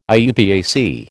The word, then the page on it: IUPAC: American English